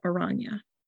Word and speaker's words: Oranje: I believe the Dutch would pronounce that Oranje.